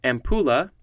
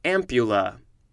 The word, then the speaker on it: ampulla: Next we have, it could be pronounced a couple different ways. First way is ampulla. Second way is ampulla. It is spelled A-M-P-U-L-L-A.